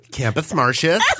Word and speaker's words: Campus Martius: The proper pronunciation is Campus Martius.